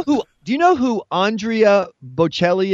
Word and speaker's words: Bocelli: Andrea Bocelli.